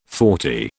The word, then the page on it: forty: British English